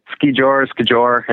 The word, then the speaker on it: skijor: Skijor. It's all pretty similar. The Norwegians and the Swedes, it's Skijor. – Okay.